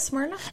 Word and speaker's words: Smyrna: No one ever knows how to pronounce Smyrna. – There is that not how you say it, Smyrna? – That is. But when people that have never lived in Georgia see it spelled, they're like, why is there a Y and an R? – I probably shouldn't say that.